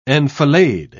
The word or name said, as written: enfilade